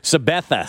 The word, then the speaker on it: Sabetha: Sabetha.